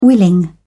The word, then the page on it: willing: British English